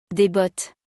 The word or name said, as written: Des bottes